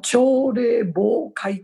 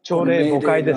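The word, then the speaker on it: 朝令暮改: で、内田さんも、中林先生も、そして、智子さんも責任者でいらっしゃるわけで、朝礼暴会って言うんですかね。朝礼暴会って言ったかね。読み方間違ってない？朝の朝礼暮会ですね。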